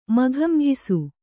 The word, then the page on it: ಮಕಮಕಿಸು: makamakisu